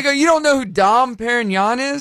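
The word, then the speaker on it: Dom Pérignon: She's like, oh, it's Dom Perignon.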